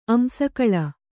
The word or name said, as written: ಅಂಸಕಳ